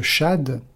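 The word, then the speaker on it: Shad (שד): Shin Dalet, qui se prononce Shad, signifie la mamelle, le sein nourricier.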